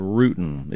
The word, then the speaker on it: rutin: It's spelled R-U-T-I-N. – R-U-T-I-N. I-N. Okay. – It almost, if I use it in a sentence and spelled it the wrong way, I'd say I'm rooting for your granddaughter. – Oh, okay. – That didn't come across real well. But rutin is R-U-T-I-N.